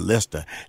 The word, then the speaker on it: Naturalista: Did I say that right, team? Lista. – Yeah, you got it. – Okay. – Got it. – That actually sounds a lot cooler now than I was saying Lista. Lista. I'm a fan now. I'm a fan. Lista. Alex Okafor and Renee. And Renee is spelled R-Y-N-A-E Lindsay.